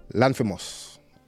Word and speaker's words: Lanthimos: Yorgos Laminoff? – Poor Things. – I want to get his name right. Lanthimos. I definitely messed it up.